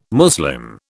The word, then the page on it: Muslim: British English